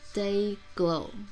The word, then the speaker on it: DYGL: 真的很喜欢这首歌，然后请恕我在这里再勘误一下刚才我念的，刚才我念的 DYGL 他们的团名应该不应该这样子念，应该要念作我刚看到我之前查的资料，应该要念成 Day Glow，然后英文的意思是荧光剂的意思，嗯，所以好，我以后看到这个团，我要说它叫 Day Glow。